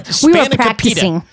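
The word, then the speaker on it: spanakopita: But Spanica pita.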